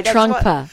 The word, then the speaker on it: Trungpa: Trungpa Rinpoche said. – You didn't say it right. – No, I didn't say it right. – Trungpa. – Trungpa. – Trungpa. – Oh, Trungpa. – T-R-U-N-G-P-A. – Trungpa. – R-I-M.